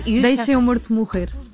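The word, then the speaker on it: Deixa o Morto Morrer: It's in Portuguese, it's very hard to pronounce Portuguese, but it's almost. – No, you Deixa Mo Morto Morrer.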